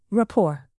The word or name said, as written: rapport